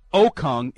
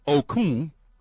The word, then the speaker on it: Okung: Three, it's not Okung, it's Okung, but a soft G. But there's still a G at the end. You still say the G. It's Kung instead of Kung. The difference is in the vowel sound, not the consonant that it ends with.